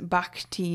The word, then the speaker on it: bhakti: And that is what I practice is bhakti yoga. That's what it's known as in the Hindu tradition. Bhakti is spelled B-H. A-K-T-I,